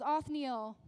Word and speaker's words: Othniel: There was Othniel.